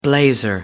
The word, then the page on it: blazer: American English